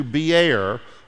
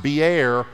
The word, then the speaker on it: Beer: It is pronounced Beer, not beer. Do not say that there's a well in the Bible that's full of beer.